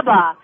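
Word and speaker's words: catuaba: Catawaba. I think. – it's pronounced several different ways, but catawaba is how we're going.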